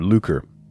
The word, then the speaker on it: lucre: It looks like lucre, but I had to go look up how to pronounce that word, lucre, which has to do with gaining money in a dishonorable or distasteful way.